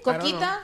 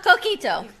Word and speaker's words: Coquito: Coquita? – Coquito.